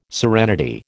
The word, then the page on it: serenity: American English